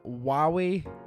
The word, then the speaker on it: Huawei: So on Google, it's saying Huawei. – That's ah, Huawei.